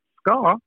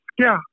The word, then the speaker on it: ska: That's not, we don't pronounce it ska as the British do. We pronounce it skia.